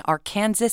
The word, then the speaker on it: Arkansas: Jody Sanderholm was born on September 26, 1987 in Arkansas City, Kansas, which is the correct pronunciation. – Yeah, it's Arkansas, apparently. That's how everybody says it there.